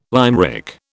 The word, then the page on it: limerick: British English